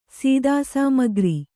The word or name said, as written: ಸೀದಾಸಾಮಗ್ರಿ